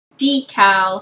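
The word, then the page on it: decal: Canadian English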